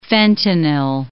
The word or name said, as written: fentanyl